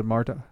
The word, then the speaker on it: Martha: It could be spelled with a T, but like I got a buddy who's whose wife is Mexican, but she pronounces it Marta. – Marta? – But it's spelled Martha.